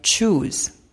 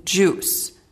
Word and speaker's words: juice: One of my clients, Peter, a business professional and eager beaver, couldn't pronounce the word juice quite the way it should sound. Instead, it sounded like choose. So I decided to take a few minutes and help him. Look, Peter, it's juice.